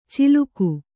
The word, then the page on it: ಚಿಲುಕು: ciluku